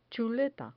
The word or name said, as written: chuleta